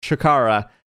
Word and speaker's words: CHIKARA: I don't know why I say it wrong, but it's CHIKARA. That is right.